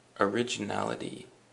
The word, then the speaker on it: originality: Originality. And the emphasis is on the na. Originality. And originality is, as I'm sure you guessed, the same thing as original, but in the noun form.